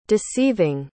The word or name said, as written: deceiving